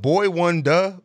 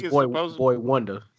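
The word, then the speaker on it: Boi-1da: Is it Boy One Duh? Boy One DA? Like, does anyone know how to say his name? – I think it's Boy Wonder. – Boy Wonder. Okay, that makes sense.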